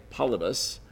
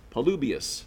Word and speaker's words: Polybius: Second century Greek historian Polybius, or I would say Polybus, or how would you say that? Polybius, there's a better one,